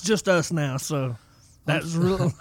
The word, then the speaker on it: Justus: Okay, so there's Just Ice, like spell it Justice, J-U-S-T-I-C-E, Justice, J-U-S-T-I-C-E. And then there's the Just Us, J-U-S-T-U-S.